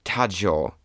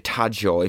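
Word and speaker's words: Tadzio: It's like Taggio. It's like Taggio if you're like saying it with a Polish accent.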